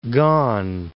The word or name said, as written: Kan